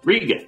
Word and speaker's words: Riga: Did I say it right? Riga? – Riga, yes. Riga. Beautiful city.